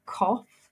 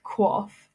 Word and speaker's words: QOF: And I was pronouncing it cough, not quaff.